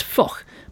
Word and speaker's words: Foch: His name was Ferdinand Foch, spelled F-O-C-H, usually mispronounced as Foch, but in fact he was a Breton, so it's Foch.